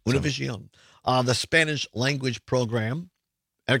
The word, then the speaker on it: Univision: Vice President Kamala Harris was on Univision last night, or the pronunciation in Spanish, Univision.